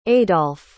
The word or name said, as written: adolph